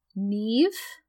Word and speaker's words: Niamh: So, like, when we meet Enid, who is played by, and I looked up on YouTube how to pronounce this name, Neve Alger. Neve is spelled N-I-A-M-H, who plays Enid.